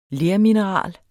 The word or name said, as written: lermineral